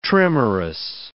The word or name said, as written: trimerous